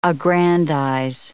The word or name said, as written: aggrandize